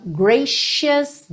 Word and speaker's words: gracious: Esse C vai ter um som de X ou de SH, ok? Sh. Então, gracious days.